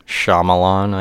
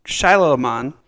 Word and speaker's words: Shyamalan: How do you say his last name? – Shyamalan, I guess. – I was looking, because I always said Shyamalan. – No, it's not that. – No, it's Shyamalan. – Shyamalan. M. Night Shyamalan, if you pronounce it phonetically.